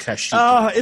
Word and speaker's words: Kashyyyk: K-A-Z-I-K. – Oh, it's getting close to Kashyyyk, man, where Chewbacca's from, so I was just wondering. – Kashyyyk, sorry. – I was going to say, I think it's pronounced Kashyyyk.